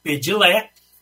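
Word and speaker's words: Padlet: A primeira é o pedilé, é como ele se escreve pedilete, é, tem um T no final, né, que é um mural que a gente pode fazer pela internet.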